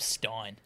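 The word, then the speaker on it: Stijn: And your name's Stein. Devolder. – Mostly your name's Stein. And that is not a soft J. That is a hard J to Stein.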